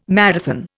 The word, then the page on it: marathon: Indian